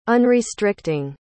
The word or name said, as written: unrestricting